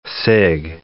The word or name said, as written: cig